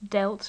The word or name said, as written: dealt